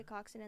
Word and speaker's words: coxswain: It's spelled like C-O-X-S-W-A-I-N. – Coxswain? – But that's how you say it.